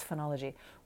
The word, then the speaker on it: phenology: It's phenology,